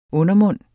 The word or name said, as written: undermund